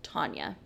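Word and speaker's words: Taunja: And if you look this up, it is spelled with a J, T-A-U-N-J-A, Tanya Bennett, but pronounced Tanya.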